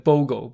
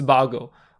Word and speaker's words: Bogle: 这位 CHXX 版友利用推文告诉我，我第一集节目里圣人杰克的英文名字 John Bogle 哦，他的姓 B O G L E 应该念成 Bogle，不是 Bogle 哦。